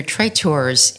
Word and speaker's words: traiteur: The male form is traiteur, and you kind of roll that R, traiteur.